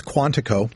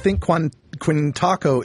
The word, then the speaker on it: Quantico: I'm giggling a little bit, Carrie, because it's Quantico. – So, I know. I don't know what I can't pronounce anything today. – I think Quintaco is close to Mindianapolis, I think.